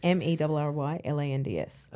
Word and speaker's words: Merrylands: And it's spelt exactly as you think, Merrylands. – Oh, double R. – Yeah. So Marylands, right? – Uh-huh. – But even our newscasters say Marylands.